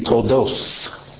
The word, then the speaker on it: Toldot: Ashkenazi is going to have the same letters, but they pronounce like the Tav, that would be Toldot for them, that would be Toldos. They pronounce that with an S.